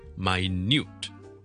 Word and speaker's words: minute: 今日咧同大家讲一个，当佢系名词同埋系形容词唔同词类嘅时候咧，佢嘅发音唔同嘅字，呢个咧系 minute 同埋 minute。 Minute 大家都知道了，分钟 M-I-N-U-T-E Minute 重音在第一个音节，